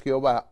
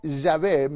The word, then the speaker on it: Yahvé: En primer lugar, debe tener el nombre, y vemos que la ley de Dios dice que el nombre es Jehová, o Yahvé, mejor pronunciado, el Eterno.